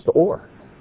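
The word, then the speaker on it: or (אור): They're both pronounced or. But one is light, one is skin.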